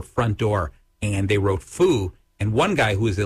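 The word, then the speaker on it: fu (福): Okay, I believe it's pronounced fu in Chinese, happiness.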